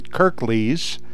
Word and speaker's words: Kirklees: I hope I say this right, Kirklees. – That's right, yes.